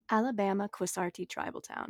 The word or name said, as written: Alabama-Quassarte